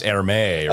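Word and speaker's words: Hermès: It's Hermes, right? It's supposed to be French.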